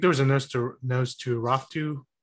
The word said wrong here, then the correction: Nosferatu: Well, how do you pronounce that, Trunks? – Nosferatu. – Yeah. Nosferatu.